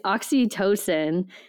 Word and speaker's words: oxytocin: it's oxytocin, mm-hmm.